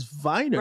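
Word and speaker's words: Weiner: And like, there is no such thing, there is no last name anywhere of name Wiener, like Anthony Wiener. Right. That's not how you pronounce that, you know? – Real name, yeah. – And so you're lending yourself to be obviously ridiculed. – Right. – Meanwhile, you could tell everybody, correct everybody, my last name is Viner.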